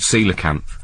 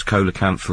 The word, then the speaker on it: Coelacanth: People look at it and they go, I want to go and see that, but I'm not sure whether it's Coelacanth or Coelacanth. – Well, even it's typical of him to choose a title for it that actually is spelt with a C but pronounced with an S, as if to avoid building up a following.